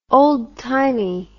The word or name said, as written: oldtimey